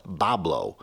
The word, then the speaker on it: Bois Blanc: Looks like it would be pronounced Bois Blanc, B-O-I-S-B-L-A-N-C. The locals call it Bablo.